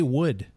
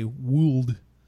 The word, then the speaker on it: would: How do you say wood? – Wood? – W-O-U-L-D. How do you say that? – Wood? – Yeah. You don't say wooled. – No, the L is silent.